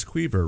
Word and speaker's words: Queaver: Were you more offended that Laura said marionette, or were you more offended that I said it's Queaver, rhymes with beaver? – Oh, I'm not offended at all.